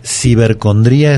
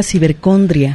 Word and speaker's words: cibercondría: Virtual, sí, en este caso es cibercondría, es una sola palabra y se escribe con acento en la I, en la última I, cibercondría. Sí, yo creí que era cibercondria. – ¿No? Cibercondría, así viene definida esta palabra con el acento.